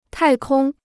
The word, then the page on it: 太空: tài kōng